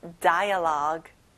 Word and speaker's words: dialogue: 끝에 U 발음은 안 나죠? dialogue.